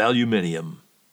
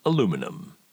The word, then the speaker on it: aluminium: The factoid is Eros was the first statue ever made of aluminium, as Brits say. The American version of that same word is, of course, aluminum.